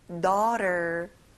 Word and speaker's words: daughter: 도터가 아니라 daughter.